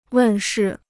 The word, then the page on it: 问世: wèn shì